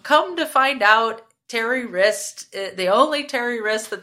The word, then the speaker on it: Wriste: And the last name is spelled W-R-I-S-T-E. So Terry Wrist.